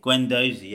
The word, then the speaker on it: Guendouzi: Guendouzi? – Guendouzi, yeah.